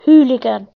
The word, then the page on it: hooligan: British English